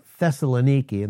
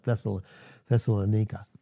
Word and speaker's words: Thessaloniki: But that's why the Holy Spirit, through Paul, said to the church at Thessalonica and in the city of Thessaloniki, and that's the proper Greek pronunciation, but it's not Thessalonica.